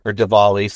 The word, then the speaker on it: Diwali: I'm pretty sure, too, when it comes to Diwali, or Diwali, sometimes it might be pronounced with a V,